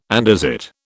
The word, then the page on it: andesite: British English